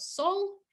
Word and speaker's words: Seoul: I'm a little bit worried on pronunciation of our South Korean country, but Seoul.